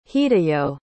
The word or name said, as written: hideyo